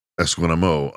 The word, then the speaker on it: Esquimaux: The actual meaning of Esquimax, Esquimo. So it's kind of like I've got a French thing going on there, like Boudreaux. Esquimo, it's spelled Esquimaux, like Boudreaux, Bordeaux.